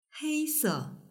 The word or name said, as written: heise